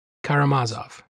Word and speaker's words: Karamazov: Karamazov.